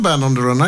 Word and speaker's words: Band on the Run: Jeg må ta meg headsetet for å nå tak i Band on the Run. Det skal uttales sånn.